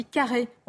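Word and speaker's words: Carhaix: On dit carré, on ne prononce ah, pas le X.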